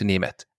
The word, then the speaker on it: Nemeth: I think his father actually changed the family name because Nemeth is a very common Hungarian family name, but it's written with an E, N-E-M-E-T-H. And the pronunciation of that word in English would be Nemeth or something like that. And he wanted at least the pronunciation to be as close to the original Nemeth as possible. So they changed the E. N-E-M-E-T-H and went with N-A-M-A-T-H because then it sounds Nemeth, which is very similar to Nemeth.